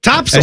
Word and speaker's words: Topsail: Topsail. – As you told me. – Oh, you said it right. – Not Topsail.